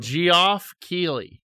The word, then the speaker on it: Geoff Keighley: Why'd you pronounce his name weird? – Geoff Keighley. – There we go. That's correct. – G-E-O-F-F.